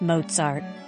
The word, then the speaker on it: Mozart: The young star was Wolfgang Amadeus Mozart. For proper pronunciation, sound out a T in the first syllable of Mozart.